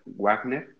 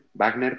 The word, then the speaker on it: Wagner: destacado maestro del Conservatorio de Richard Wagner, Wagner perdón, tiene experiencia en recitales y presentaciones de solista.